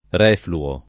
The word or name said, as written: refluo